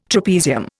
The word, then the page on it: trapezium: Indian